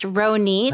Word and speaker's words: Ronit: And my name is pronounced Ronit. – Hi, Ronit. – Which rhymes with so neat.